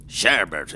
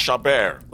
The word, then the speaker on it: Chabert: Sherbert, is it? Do come in. – It's pronounced Chabert.